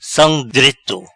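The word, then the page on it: Sam dreto: Patua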